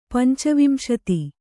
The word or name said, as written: ಪಂಚವಿಂಶತಿ